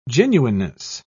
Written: genuineness

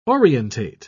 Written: orientate

